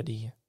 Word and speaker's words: Digne: Hoe spreek je hem ook uit? Luca Digne. Luca Digne, precies. Is Frans.